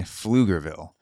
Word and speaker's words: Pflugerville: No connection to this article, but I've never actually seen the word Pflugerville written out, and apparently it starts with a P. So that is a surprise. A silent P in Pflugerville.